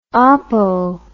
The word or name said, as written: oppo